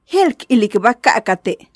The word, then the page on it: Jeél k ilikba ka'ate': Maya